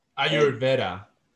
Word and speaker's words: Ayurveda: Ayurveda.